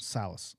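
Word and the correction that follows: Saleh: Is that right? – Salah.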